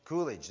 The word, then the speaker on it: Coolidge: I think I said it right. Coolidge. There we go.